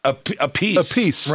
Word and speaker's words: Appice: And there's another Appice that doesn't pronounce the last name the same way. – It's his brother. – A piece. Right.